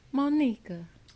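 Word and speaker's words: Monika: And then don't forget, we got our guest host, Monica.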